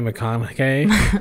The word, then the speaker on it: McConaughey: It's Matthew McConaughey.